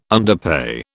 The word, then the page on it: underpay: British English